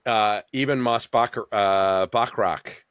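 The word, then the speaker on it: Ebon Moss-Bachrach: And then Eben Moss Bachrock.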